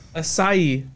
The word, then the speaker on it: acai: Acacia? – Pink acai.